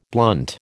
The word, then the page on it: blunt: American English